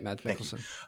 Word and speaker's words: Mads Mikkelsen: I had no idea whatsoever, but now that Grindelwald's recast with Mads Mikkelsen, if I'm pronouncing it. Yeah, Mads Mikkelsen.